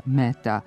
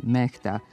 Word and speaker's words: Mehta: Na hindi jeziku, koji je jedan od službenih u Indiji, odakle je Mehta rodom, njegovo prezime zaista se izgovara Mehta, a pisani oblik preuzeli smo iz engleskog. Međutim, ako se uzme u obzir pravopisna preporuka da strana imena koja su ušla u naš izražajni običaj ne treba menjati, onda prezime čuvenog dirigenta treba da ostane Mehta, jer ga tako izgovaramo više od pola veka.